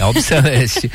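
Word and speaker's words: Albiceleste: Da Albiverde, Albi, como é que fala, Bárbara? É Albi, Albi Celeste, não é isso? – Albi Acho Celeste. – Que sim. – Albi Celeste. Pois é, tinha muita gente com a Albi Celeste.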